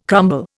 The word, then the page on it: crumble: Indian